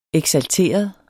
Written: eksalteret

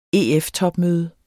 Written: EF-topmøde

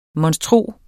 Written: monstro

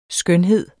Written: skønhed

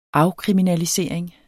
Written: afkriminalisering

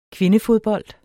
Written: kvindefodbold

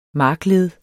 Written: markled

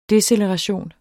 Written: deceleration

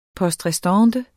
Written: poste

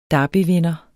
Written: derbyvinder

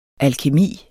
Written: alkemi

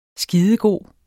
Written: skidegod

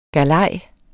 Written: galej

